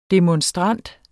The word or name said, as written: demonstrant